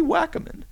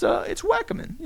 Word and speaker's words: Wackiman: If you want to tweet me, if that's your preferred medium on Twitter, you could tweet me at Wally Wackerman. Now, let me explain how that's spelled. Wally is W-A-L-L-Y, simple enough. And then Wackerman, W-A-C-K-I-M-A-N. It's not Wackerman. It's not Wacky Man. It's Wackerman.